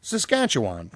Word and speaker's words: Saskatchewan: Saskatchewan.